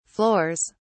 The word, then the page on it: floors: British English